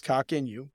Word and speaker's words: Coq Inu: It's C-O-Q, and then N-U, C-O-Q standing for a French word for chicken, but it is pronounced Coq Inu, but it has seen significant growth.